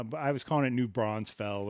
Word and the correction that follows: New Braunfels: or something okay. like that it's new braunfels okay.